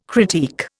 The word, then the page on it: critique: Indian